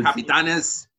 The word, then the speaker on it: Capitanes: So there's going to be a G League in Mexico, and um, they're calling it the Capitans. I, I hope that's how you pronounce it. – No, it's well, C- it's uh, the Capitanes. – Yes, it's Capitanes, and the team already existed.